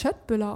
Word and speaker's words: Köttbullar: Wird das Köttbullar ausgesprochen? Mhm. Also gar nicht mit K, so wie wir das von Ikea kennen? Da sagt ihr Köttbullar. – Mhm. Mit Sch.